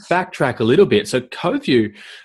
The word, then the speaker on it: Coviu: And so now as an extension of that, we've got a video consulting platform inside the browser called CoView, which is spelt a little bit strangely, C-O-V-I-U. And so CoView